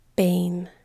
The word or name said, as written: bane